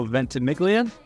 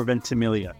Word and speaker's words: Ventimiglia: It's closer than most people get. You could go Ventimiglia or Ventimiglia.